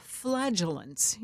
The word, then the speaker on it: flagellants: Make sure you pronounce that correctly. – Flagellants. Flagellants. – Yes. – Not with a T.